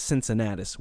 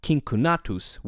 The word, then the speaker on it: Cincinnatus: In Latin, his name would have been pronounced Cincunatus with hard C's, but since the name is more familiar in the English language in its anglicized form with soft C's, I'm going to stick with that pronunciation over the course of this episode.